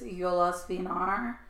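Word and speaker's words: Jólasveinar: You can pronounce, I found the pronunciation is Yolas Vinar, which when you see the thing, it looks like Veen, but to me, it might actually be Vien, Yolas Vinar.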